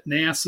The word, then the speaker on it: Nass: Nass is good.